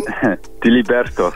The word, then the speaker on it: Diliberto: Diliberto.